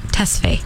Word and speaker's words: Tesfaye: Tesfaye.